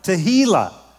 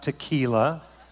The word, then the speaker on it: tehillah: Here's the third one, tehillah. Tehillah, this is to sing a song of thanksgiving. Now, you need to make sure you pronounce that correctly. It's not tequila. It's tehillah.